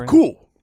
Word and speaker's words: Roku: Roku.